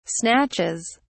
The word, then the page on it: snatches: British English